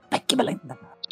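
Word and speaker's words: pechblenda: E como ela já esperava, ela encontrou esses raios invisíveis muito similares com os do urânio em uma pedra chamada Ekblenda, Gente, é, eu vou pronunciar não, assim. O nome, o nome é Peck Blenda mesmo. Peck Blenda.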